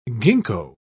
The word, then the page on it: gingko: British English